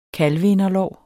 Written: kalveinderlår

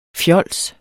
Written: fjols

